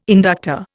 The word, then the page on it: inductor: Indian